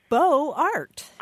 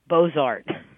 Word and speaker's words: Beaux-Arts: It should be Beaux-Arts without the T at the end. – That's hyper-foreignism. – That's actually correct French. The hyper-foreignism is to actually get the elision between the X and the A correct, but actually leave the T on, which is an anglophone way of pronouncing the word.